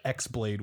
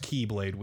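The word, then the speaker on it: Keyblade (χ-blade): It's not the cross. It's pronounced Keyblade Wielder because it's like the Greek for X is key.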